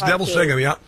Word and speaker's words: Neville Singham: Very wealthy man, Neville Singham, I think I'm pronouncing his name correctly. – It is. It's Neville Singham,